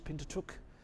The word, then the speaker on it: Pentateuch: So you've got the Torah, or the, I never know how to pronounce that other word, the Pentateuch. Yep, there you go. If you didn't hear it, you can ask Brian. I didn't hear what he said, but he corrected me.